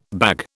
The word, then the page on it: bag: British English